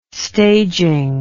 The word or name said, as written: staging